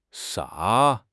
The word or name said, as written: 啥